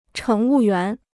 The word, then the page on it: 乘务员: chéng wù yuán